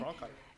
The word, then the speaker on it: Cronkite: Cronkite.